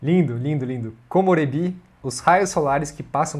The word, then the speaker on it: komorebi: Esses dias aprendi com o João Pedro, meu professor de japonês, a palavra komorebi. Aí você pode me dizer como que é a pronúncia correta? – Komorebi. – Komorebi.